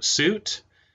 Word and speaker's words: Suite: As I said, my name is Andrew Suite, spelled like sweet, S U I T E.